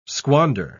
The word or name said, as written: squander